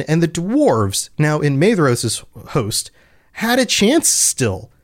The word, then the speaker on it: Maedhros: And in the confusion that they wrought, they came near to the standard of Maedros. And I just realized I've been doing it again. Pronunciation is rough. Maedhros—it's It's a DH. I talked about this on the last episode, and yet I forgot. Maedhros, by the standard of Maedhros.